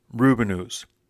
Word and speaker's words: Rubinoos: Uh, to be fair, I did check with Jordan Oakes of Yellow Pills magazine, and he confirmed that yes, it is supposed to be pronounced Rubinoos, in fact, if you look on the band's website, which is com, they do have a pronunciation guide. It says rhymes with two canoes.